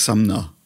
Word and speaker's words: Sumner: nämlich Sumner. Ne, weil Sumner's Tales wird anders geschrieben, aber klingt von der Aussprache genau wie der Nachname.